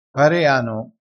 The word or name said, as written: pareano